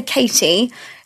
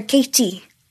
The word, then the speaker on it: Katy: okay, so if I was going to say my name in a Welsh accent, I'd say Katy. The Welsh would go Katy. – Okay. – So it's more softer. It's kind of more husky.